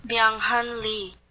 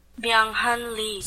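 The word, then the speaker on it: Byung-hun Lee: Byung Hun Lee. – Is that how you say it? Say it again. – Byung Hun Lee.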